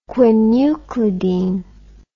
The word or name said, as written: quinuclidine